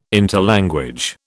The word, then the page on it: interlanguage: British English